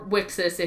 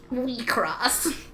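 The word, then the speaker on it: WIXOSS: Or Wixus if you're a fucking dumb American. – It's pronounced Wecross.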